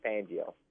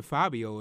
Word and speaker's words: Fangio: Say his last name one more time. – Vic Fangio. – Oh, I thought he said Fabio or something.